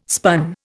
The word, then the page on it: spun: Indian